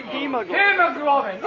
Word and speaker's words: hemoglobin: And number three, and that is the most sensitive one, you know this something in our blood called hemoglobin? – Hemoglobin. – How do you say that? – Hemoglobin.